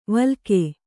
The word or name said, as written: ವಿಲೋಕ